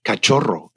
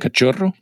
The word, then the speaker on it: cachorro: That's a hard one to pronounce. Right? – Cachorro. – You got a CH and a double R in there. Cachorro.